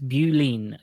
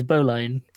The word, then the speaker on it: Bowline: Yeah, it's also so clearly spelled Bowline. – Yeah, it's Bowline. – Like, it's spelled Bow, like Bow. – Yep. – And then Line, like Line.